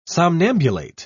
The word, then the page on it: somnambulate: British English